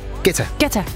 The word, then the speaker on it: Guetta: Dengang jeg arbejdede på Bruxelles Radio, der sendte han engang en fax om, at hans navn udtales Guetta. Og ikke Guetta, som alle os andre havde grund at kalde ham.